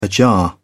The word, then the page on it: ajar: British English